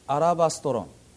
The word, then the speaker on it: アラバストロン: ところがこの最近のですね、考古学あるいは言語学の研究によりまして、この石膏の壺と訳されてきたギリシャ語のアラバストロン、正確にはアラバストロンと言いますが、アラとラのところにアクセントがあるんですね。アラバストロン、これは、当時シリアのシドンを中心に作られていた、いわゆるローマングラス、すなわちガラス製の細首瓶であるということが分かってまいりました。